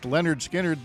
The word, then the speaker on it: Lynyrd Skynyrd: Because Lynyrd Skynyrd released their debut album, pronounced Lynyrd Skynyrd, this day in 1973.